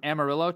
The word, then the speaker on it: Amarillo: You're from Amarillo, Texas.